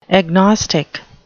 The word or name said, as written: agnostic